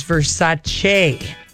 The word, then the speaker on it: Versace: Have you been pronouncing V-E-R-S-A-C-E wrong? – Versace? – What is that? Wrong. It is Versace. – I just said that. – You Versace. Said Versace. – Oh, Versace. – It's Versace. – Che. Yeah. – Like, what? Che.